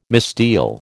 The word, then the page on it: misdeal: American English